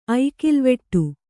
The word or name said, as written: ಐಕಿಲ್ವೆಟ್ಟು